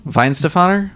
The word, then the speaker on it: Weihenstephaner: Oh, Weihenstephaner? – Yeah.